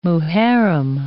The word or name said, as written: Muharram